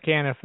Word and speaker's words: Caniff: Yes, Caniff.